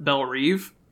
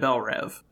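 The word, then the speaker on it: Belle Reve: Except they do mispronounce it in the movie multiple times and call it Belrive. – Interesting. – And it bothered me every time they said it, because it's definitely always been Belrev.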